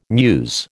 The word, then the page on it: mews: American English